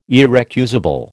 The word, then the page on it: irrecusable: American English